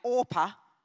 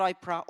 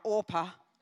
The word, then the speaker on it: Orpah: The two sons marry Orpah, not Oprah, Orpah. And it's spelled this similar.